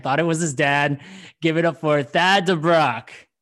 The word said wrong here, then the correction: Thad: You know, my name's actually Tad, but it's spelled T-H-A-D. – Oh, so it is Tad. I mean, I've been calling you Thad and Tad. – It's spelled T-H-A-D.